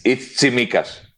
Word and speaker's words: Tsimikas: It's Tsimikas.